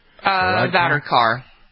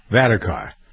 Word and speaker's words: Varadkar: The Prime Minister of Ireland, his name is Leo, is it Varadkar? Is that how you pronounce his name? – Varadkar. – Varadkar.